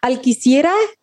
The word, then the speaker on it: Alquicira: Alquicira? Is it? – Sierra? Yes. Oh, okay. Yeah. You pronounce it perfectly. It's a tough last name even in Mexico, but you did it just right.